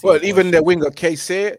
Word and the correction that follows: Chiesa: Fam, man. It's Chiesa.